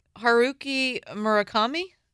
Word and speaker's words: Haruki Murakami: Haruki Murakami? – That's as I understand it, that's correct.